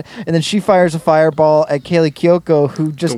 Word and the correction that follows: Cuoco: No, it's Cuoco. It's Italian, not Japanese.